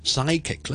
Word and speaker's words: psychic: 通常咧就係一啲咧來自希臘文嘅 PS 啊、 PN 啊嗰啲字，通常個 P 都唔讀嘅。咁俾例子大家，pneumonia 其中一個啦，psychic 啦，psychic psychic 即係一個有通靈能力嘅人啊。